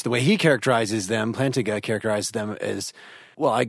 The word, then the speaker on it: Plantinga: The way, is it Plantinga? Is that how to pronounce his name? Oh goodness! It is tonight. Rob, what do you think? – Yeah, there you go. yeah, that's the way.